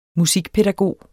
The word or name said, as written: musikpædagog